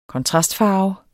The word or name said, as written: kontrastfarve